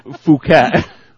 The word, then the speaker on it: Phuket: It's actually pronounced Phuket.